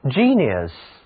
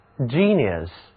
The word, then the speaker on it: genius: 所以我们要记住这个词的写法以及读音，字母 iu 它读作 ear， genius genius 听起来啊有点像那个吉尼斯世界纪录的吉尼斯，对不对？